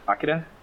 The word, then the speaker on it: Akira: Is that Akira? That's really how they pronounce it.